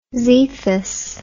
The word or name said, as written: zethus